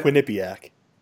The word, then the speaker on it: Quinnipiac: Quinnipiac? Is that how you say it? – Quinnipiac.